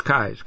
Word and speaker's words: Keyes: Kai's Peak Uphill Truck Drags on Facebook is the place to look, and Kai's is spelled K-E-Y-E-S, like keys, but it's Kai's.